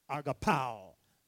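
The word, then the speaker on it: agapao (ἀγαπάω): There's only the one word agape of God, and now it's we agape our neighbor too, or pronounce it more accurately, agapao.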